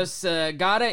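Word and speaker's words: Sagada: How did I say we pronounce this? Sagata. Okay. – Yeah.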